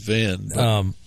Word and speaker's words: Hven: I think it's pronounced Van.